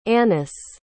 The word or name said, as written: anice